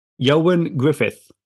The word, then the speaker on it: Ioan Gruffudd: And I'm going to tell you, it is not how it looks. It is Ioan Gruffudd.